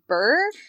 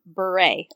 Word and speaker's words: Bure: And Kim pointed out to me this week that she saw a commercial for a Hallmark movie that Candace was talking, and apparently I've been saying her last name wrong. So Candace, if you ever listen to this, I apologize for the last three episodes saying Candace Cameron Bure, because apparently it's Candace Cameron Bure.